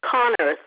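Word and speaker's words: Konnerth: Christine Connor. The last name is spelled with a K-O-N-N-E-R-T-H.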